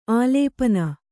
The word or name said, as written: ಆಲೇಪನ